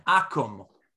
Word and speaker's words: akum: It's called an akum. Akum. In English, it's spelled A-K-U-M, but it's really an acronym.